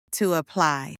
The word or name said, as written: to apply